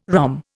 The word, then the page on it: ROM: Indian